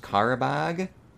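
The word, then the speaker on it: Qarabağ: They win their game against, I think this is pronounced Karabag.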